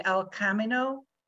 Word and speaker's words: El Camino: And I'm actually training somebody right now remotely who wants to do the El Camino walk in Spain.